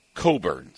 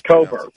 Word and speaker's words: Cockburn: And I've been told it's really Kofi Coburn. So yeah, Coburn. Okay. – I mean, that's what I would tell people, too, if my name were that. – Exactly. – Yeah, it's you spell it, you pronounce it Coburn, of course.